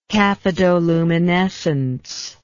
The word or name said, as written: cathodoluminescence